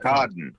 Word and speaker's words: Arkadin: Arkadin. – Arkadin. Thank you. See, there's my lazy American voice taking out the opportunity to relax around the big ah sound.